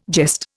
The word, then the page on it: jest: Indian